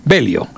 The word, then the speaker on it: Beljo: Belgio, ¿cómo se escribe? ¿Se deletrea? – B-E-L-J-O. – Belio, entonces es Belio el autor del empate.